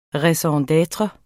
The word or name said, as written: raison d'etre